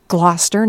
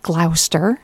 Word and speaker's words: Gloucester: And last weekend, actually, Jim and I were up in Gloucester, Massachusetts, and I finally learned how to pronounce that correctly. Gloucester, not Gloucester.